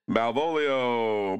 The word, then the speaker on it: Malvolio: Malvolio, Malvolio, Malvolio, come on, Malvolio.